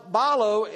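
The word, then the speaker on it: balo (βάλλω): It means, it's pronounced balo in the original language.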